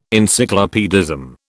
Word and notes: encyclopaedism: British English

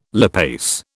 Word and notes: lipase: British English

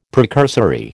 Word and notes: precursory: American English

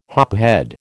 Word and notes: hophead: American English

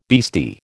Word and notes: beastie: American English

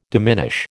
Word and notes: diminish: American English

